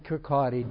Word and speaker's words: Kirkcaldy: He preaches in Kirkcaldy, K-I-R-K-C-A-L-D-Y. It looks like Kirk, the word for church. Caldy, C-A-L-D-Y, but pronounced by the Scots, Kirkcaldy.